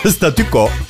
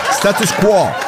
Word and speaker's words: status quo: Statükö. Orijinali status quo'dur bunun. Vaziyet diyemedik. Aynen telaffuz ettiğimiz gibi Türkçe yaptık. Hadi tebrik ediyorum bizi. Statükö. Status quo.